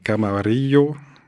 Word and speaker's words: Camarillo: Zappa prononce Camarillo, le nom d'une ville de Californie, pour rimer avec Brio, un nom commercial associé à des chiffons de nettoyage.